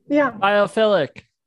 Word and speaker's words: biophilic: Is this just how does those compare when I hear that title versus biolithic? – I've never heard anyone say biolithic, so I don't know. But okay. – Biophilic. – Okay, fine. – I think Brett's just that word. – Pronouncing this. It's biophilic. Brett.